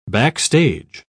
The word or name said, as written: backstage